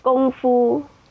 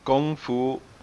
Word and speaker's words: Gong Fu: Gong Fu. – Gong Fu. – Yes. The Kung part of it is a very Cantonese pronunciation. – Okay. – With a K.